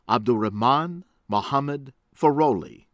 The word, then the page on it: Abdurahman Mohamed Farole: American English